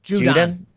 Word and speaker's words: Judon: It's Judon. Is how it's pronounced.